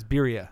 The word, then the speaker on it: birria: Yes, birra. – I told birria. You it was spelled like that. – It's birria.